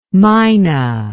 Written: minah

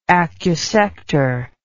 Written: acusection

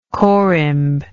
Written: corymb

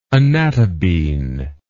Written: anatabine

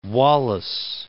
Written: Wallis Islands

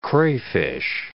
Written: crayfish

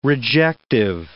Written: rejective

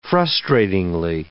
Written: frustratingly

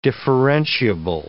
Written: differentiable

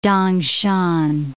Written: Tangshan

